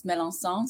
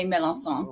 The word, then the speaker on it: Melançon: It's my mom's last name. It's Melançon, so it's Mei Melançon. Oh. So it's like the C is an S.